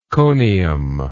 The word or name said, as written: conium